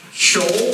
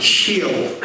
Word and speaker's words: Sheol: And this is the easiest way I can tell you to pronounce Sheol. What do we have here so far? That's right. This is how you pronounce it. Sheol. Sheol.